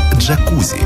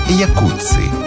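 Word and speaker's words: Jacuzzi: Это и есть то, что мы называем словом «джакузи». Хотя правильнее было бы сказать «якутси». Именно так в действительности произносится фамилия тех самых братьев-итальянцев.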